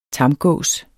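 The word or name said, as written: TAMGÅS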